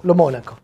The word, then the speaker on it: Lomonaco: Lomonaco.